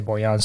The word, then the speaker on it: Borjan: Most notably, Milan Borjan, their goalkeeper, who have been pronouncing it Borjan this entire time, but I heard the announcer say Borjan, so here we go.